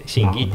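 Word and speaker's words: 清規: 清らかな規律と書いて真偽って読むんですけど、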